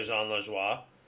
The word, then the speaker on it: Lajoie: It's like it makes me think of like a Jean Lajoie. Where you're like, did I say that right? – Yeah. – John LaJoie? Or... – No, you had it right the first time. Congratulations.